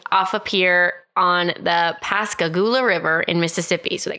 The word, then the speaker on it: Pascagoula: So I am going to be talking about the Pascagoula abduction, and I'm very sorry if I'm saying that wrong.